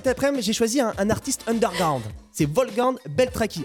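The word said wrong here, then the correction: Beltracchi: Alors, je le prononce mal parce qu'en réalité, ça se dit comme ça. – Wolfgang Beltraki.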